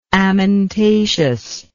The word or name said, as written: amentaceous